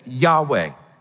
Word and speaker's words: Yahweh: We try to pronounce it Yahweh, which is a made-up pronunciation, because it's the Hebrew consonants, yod, he, vav, he.